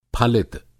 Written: फलित

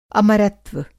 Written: अमरत्व

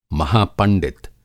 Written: महापंडित

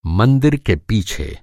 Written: मंदिर

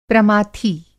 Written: प्रमाथी

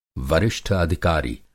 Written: वरिष्ठ-अधिकारी

